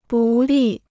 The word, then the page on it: 不利: bù lì